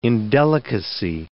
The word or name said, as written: indelicacy